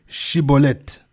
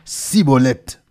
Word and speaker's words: Shibolet: Il lui disait alors : eh bien, dis Shibolet. Et il disait « Sibolette », car il ne pouvait pas bien prononcer.